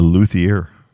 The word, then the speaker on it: luthier: What do you call a guitar maker? A luthier. – Okay, a luthier. Yeah, you said it right.